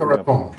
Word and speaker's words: Boca Raton: Boca Raton. Eller Raton. Det är också uttalat. Boca Raton.